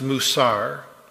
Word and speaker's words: musar: Instruction there is the Hebrew word musar, spelled M-U-W-C-A-R, pronounced musar.